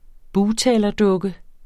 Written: bugtalerdukke

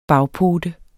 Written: bagpote

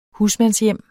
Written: husmandshjem

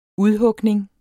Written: udhugning

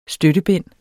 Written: støttebind

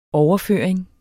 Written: overføring

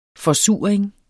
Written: forsuring

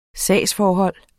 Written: SAGSFORHOLD

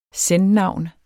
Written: sen-navn

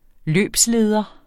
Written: løbsleder

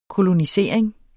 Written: kolonisering

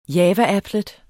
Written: Java-applet

